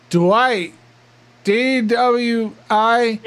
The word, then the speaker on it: Dwight: D-W-I. – Dwight? – Yeah. – And what about your last name? Dwight? – No, why do you pronounce Dwight like that? You pronounce it like DeWalt, like I'm a drill or something.